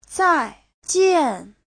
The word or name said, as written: zai4jian4